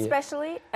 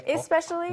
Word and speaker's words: especially: 그리고 especially 같은 경우에는 사람마다 그 이 부분에 대한 발음이 살짝 다른데 어떤 사람은 좀 더 이에 가깝게 especially, especially, especially. – 그리고 especially처럼 발음하십니다. – Yeah. Especially, 네.